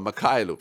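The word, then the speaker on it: Mykhailiuk: how do you say that, McCalliuk, Makailuk, joo, Makailuk, vitsi miten vaikea nimi, Siavatoslav Makailuk, 15 pistet, 5 syöttöä, 5 levyä, kaunis joukkue.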